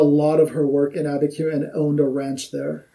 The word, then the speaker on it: Abiquiu: And if you're wondering why, I am going to be talking about a place called, and we always called it Abiquiu, but it could be something pronounced differently. – No, that is correct. It's Abiquiu.